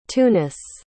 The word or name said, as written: tunis